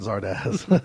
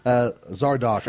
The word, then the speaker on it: Zardok: No, not Zardoz. Zardok, Sean I guess Connery. Is how it's pronounced. Z-A-R-D-O-K.